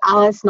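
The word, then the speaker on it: Alyss: My name is Alice Nolan, and that's A-L-Y-S-S. So it looks like I butchered Alyssa.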